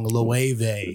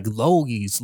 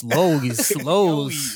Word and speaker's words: Loewe: For the audience at home, again, don't catch yourself out with the fashion girly pronouncing it wrong. Loewe. – Yeah, my homie My homie from New Orleans, he was like, Logies. – Loewe.